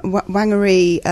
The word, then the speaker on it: Wangiri: And apparently, um, Wangiri, um, that's how you say it, originates from Japan.